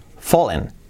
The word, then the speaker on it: fall: It's fallen. To fall es caer. Escrito fall, pero la pronunciación es fall. La A como una O.